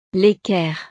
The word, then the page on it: l'equerre: frances